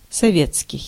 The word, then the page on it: советский: /sɐˈvʲet͡skʲɪj/